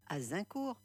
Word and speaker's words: Azincourt: Le chant ou carole d'Agincourt, que l'on prononce aussi Azincourt, est une chanson folklorique anglaise écrite au début du XVe siècle.